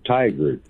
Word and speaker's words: Tigard: It's called Tigard, Oregon. – Tigard? Okay, I want to make sure I pronounced it correctly. It sounds like a racial slur. I want to make sure I'm pronouncing it correctly. – it almost sounds like tiger, but it's tiger. It's like T-I-G-A-R-D.